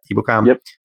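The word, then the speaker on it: Ebukam: I was shocked that they signed Samson, and I'm going to butcher his last name too, but Ibukam, is that how you say it? Ibukam. – Yep.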